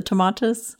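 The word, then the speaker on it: Tomatis: And then would you bring in the, you mentioned the tomatas method. Is that how you pronounce it? The tomatas? You, you did great.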